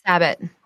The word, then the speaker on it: Sabat: Am I saying it right? – Sabat. – Sabat. Damn it.